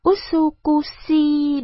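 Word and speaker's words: Usukushi (うつくしい): Usushi là đẹp. Lúc ấy, Miyazaki nhận ra rằng mình phát âm tiếng Nhật sai. Đọc là Usukushi đúng không?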